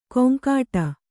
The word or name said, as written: ಕೊಂಕಾಟ